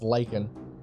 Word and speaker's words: lichen: The fungus is called a black lichen. – Hold on. L-I-C-H-E-N? – Yes. – Okay. That's lichen. That's lichen. – It's pronounced lichen here.